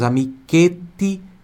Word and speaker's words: amichetti: Amichetti é amiguinhos. Repita em voz alta. Ó, amichetti. Pronúncia por do ti aqui.